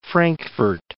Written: Frankfort

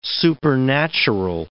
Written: supernatural

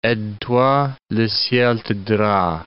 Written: aide-toi, le ciel t'aidera

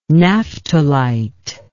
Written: naphtalite

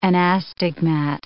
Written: anastigmat

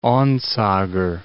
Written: Onsager

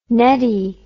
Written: nettie

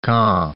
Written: Caen

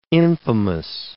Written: infamous

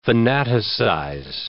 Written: fanaticize

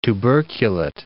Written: tuberculate

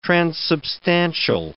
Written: transubstantial

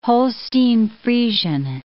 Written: Holstein-Friesian

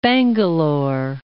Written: Bangalore

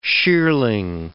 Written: shearling

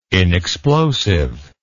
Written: inexplosive

